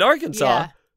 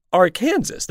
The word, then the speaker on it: Arkansas: and Arkansas should just be Arkansas.